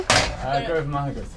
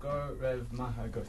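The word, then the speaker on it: Go raibh maith agat: How do you say thank you? – Garv Mahagut. – Say it again. What? – Garv Mahagut.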